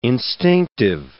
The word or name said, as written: instinctive